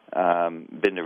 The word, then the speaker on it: Bandar: Sultan, who's the defense minister, his son, Ambassador Bandar, which I am. Binder really is the way they pronounce it there in Saudi Arabia, but Bandar, who's been the ambassador to the United States since 83, a number of other key players in the government.